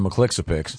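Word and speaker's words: Mxyzptlk: What did you call the imp in Superman comics? – I didn't read... – Remember the unspellable... – I'm envious because you have a pronouncer for that. I called him Mr. McClixapix. – See? You nail it.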